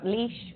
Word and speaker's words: Laois: You got Laois, perfect.